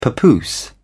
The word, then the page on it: papoose: British English